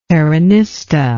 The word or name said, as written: peronista